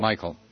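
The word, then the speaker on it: Michel: Bob Michael or Mitchell? – Michael.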